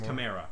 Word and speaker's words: Chimera: I've heard it pronounced both ways, so I don't know. – Wrong, it's Chimera.